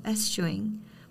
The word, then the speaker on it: eschewing: 아, 두 번째 단어는 생각보다 발음하기가 좀 어려워요. Eschewing. 그쵸. 추, 에스추 할 것 같은데 에스추잉이라서 조금 어려웠습니다.